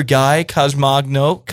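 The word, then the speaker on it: Guy Consolmagno: Joining you will be Brother Guy Consomagno.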